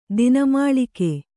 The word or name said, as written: ದಿನಮಾಳಿಕೆ